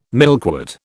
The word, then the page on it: milkwort: British English